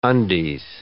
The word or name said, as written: undies